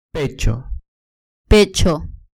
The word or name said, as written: pecho